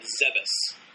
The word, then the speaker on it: Zebes: for the longest time, I thought it was Zebes up till... Once Other M came out, when Sakamoto actually, well, throughout the advertisement, the right pronunciation is Zebes.